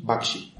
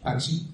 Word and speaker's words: Bakshi: Bakshi. – Bakshi, exato.